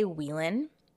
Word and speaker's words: Whelan: Now, I've heard Whelan and Whelan. I'm going to say Whelan because it's spelled W-H-E-L-A-N.